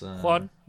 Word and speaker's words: Juan: He's called Juan. Isn't that how you pronounce... – Juan, yeah.